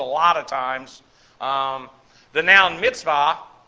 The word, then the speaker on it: mitzvah: But when you listen to Jewish people speak, you'll hear them pronounce it as a V. Mitzvah, bar mitzvah.